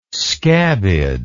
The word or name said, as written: scabbed